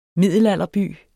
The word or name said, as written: middelalderby